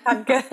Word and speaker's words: Katka: Is that how you say her name? Katka. Yeah. Katka. Katka made you do that.